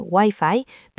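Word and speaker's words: Wi-Fi (ワイファイ): Pueden usar la palabra inglesa, Wi-Fi, pero muéstranos la pronunciación japonesa. – Wi-Fi. – Tiene un sonido distinto.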